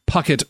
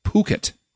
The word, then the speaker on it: Phuket: It's not Puckett or Phuket. It's Phuket. Okay. The H is silent.